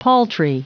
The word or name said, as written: paltry